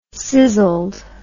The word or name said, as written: sizzled